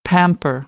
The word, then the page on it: pamper: American English